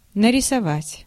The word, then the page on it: paint: /peɪnt/